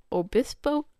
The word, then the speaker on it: Obispo: O-B-I-S-P-O. Obispo? Obispo?